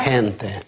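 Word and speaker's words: gente: Если после Г идет А, О, У, то читается Га, Го. А если Э и И, то Хе, Хи. Например, Ла Хенте.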